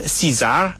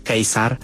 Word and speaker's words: Caesar: W Anglii na przykład mówiło się Caesar, a u nas mówiło się Cezar, a tak naprawdę starożytni Rzymianie raczej mówili Kejsar.